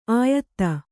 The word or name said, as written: ಆಯತ್ತ